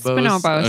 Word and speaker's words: Bonobos: Am I saying that right? Bonobos? Bonobos? – It's Bonobos. Okay. It's like how you say the monkey.